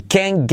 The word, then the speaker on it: can't: I can't, ahora veremos el can't, que no pronunciamos la T. I can't get over his loss.